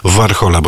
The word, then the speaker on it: Warhola: To jest niezwykłość i to też jedna z ważniejszych płyt, Mama Warhola, bo tak powinno się wymawiać ze słowackiego nazwiska późniejszego króla popartu Andy Warhola.